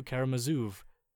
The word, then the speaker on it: Karamazov: Karamazov. Karamazov? Not like the Z in Mozart. The Z in Zoo and Mozart.